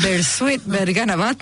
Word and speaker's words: Bersuit Vergarabat: Bersuit Vergarabat. – Bersuit Vergarabat.